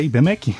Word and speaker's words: IBMEC: IBMEC. Falei certo da é IBMEC? – É IBMEC.